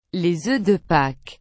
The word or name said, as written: les oeufs de paques